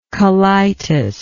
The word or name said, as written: colitic